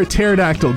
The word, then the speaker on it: pterodactyl: Why can't you hear a pterodactyl going to the bathroom? – Because the P is silent.